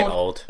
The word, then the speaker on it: Auld: And in Scottish, it's spelled A-U-L-D. But it's just say old. – It's pronounced old. – Yeah. – It means old.